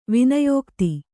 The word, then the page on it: ವಿನಯೋಕ್ತಿ: kannada